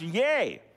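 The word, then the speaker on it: ye (γῆ): Well, the word that is used here for earth that John describes is the Greek word ye, okay? G-E, it's pronounced ye.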